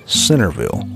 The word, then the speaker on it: Centerville: It's pronounced Centerville, like S-I-N-N-E-R.